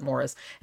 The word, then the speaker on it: Maurice: Morris or Maurice. It's spelled Maurice, but I know some in the UK, sometimes they pronounce it Morris, and he pronounces it Morris in the movie.